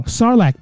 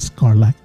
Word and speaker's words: Sarlacc: I'm saying it wrong. Sarlacc pit. Not Scarlacc. Sarlacc pit.